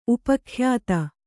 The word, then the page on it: ಉಪಖ್ಯಾತ: Kannada